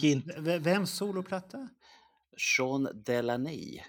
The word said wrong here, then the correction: Sean Delaney: Jag tror det uttalas så. – Vem är det? det var han, han menar Sean Delaney.